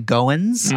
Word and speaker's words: Goins: uh, it's often, you know, I live in the South, so they say Goins.